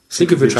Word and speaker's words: Sienkiewicz: Geschrieben wurde das Ganze von Brian Michael Bendis, gezeichnet von Bill Sienkiewicz. – Bill Sienkiewicz. Sienkiewicz heißt der, glaube ich. – Ja.